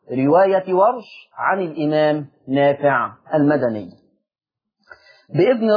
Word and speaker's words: ورش: بفتح الواو وسكون الراء، ورش.